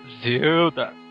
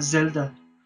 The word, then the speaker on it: Zelda: Como é que pronuncia isso, cara? Zelda. – Ah, Zelda? – Zelda, Zelda.